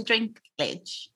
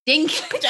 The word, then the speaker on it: Dinklage: They did, especially, is his name Peter Dinklage? – Dinklage. – I knew I was saying it wrong.